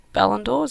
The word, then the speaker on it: Ballon d'Or: For anyone that doesn't know, it's the Ballon d'Or. Is that how you pronounce it? – Yeah. – Got it right.